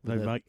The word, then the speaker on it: Shinoda: Shinoda. An elongated A, I think he pronounces it.